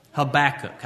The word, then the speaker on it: Habakkuk: Now, this is possibly the most mispronounced book in all of the Bible, Habakkuk.